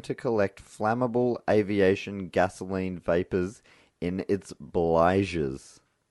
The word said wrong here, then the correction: bilges: How do you spell that? – B-L-I-G-E-S. No, B-I-L-G-E-S. Bilges. – Bilge.